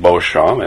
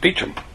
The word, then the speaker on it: Beauchamp: It, it looks as if it should be pronounced Beauchamp, and in French it is, but in England, if you look at the Oxford Dictionary, it's pronounced Beecham what what I'd like to do with our time together is to look at that.